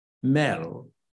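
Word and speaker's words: mél: So they say mail, but it can't be spelled M-A-I-L. Because that would be mine. So they start saying, so no, you're going to spell it M E with an accent L, Mel.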